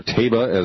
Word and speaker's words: Taiba: He's linked to Lashkar-e-Taiba, or Taiba as some Pakistanis pronounce it.